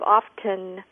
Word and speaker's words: often: But the pronunciation of often with the T in there is acceptable. It's not incorrect, but it was inspired by the spelling.